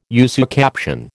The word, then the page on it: usucaption: American English